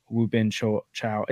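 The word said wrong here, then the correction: Won-Bin Cho: is that how you say it? – Wubin Chow? It's Wonbin Chow.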